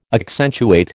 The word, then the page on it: accentuate: American English